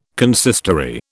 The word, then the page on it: consistory: British English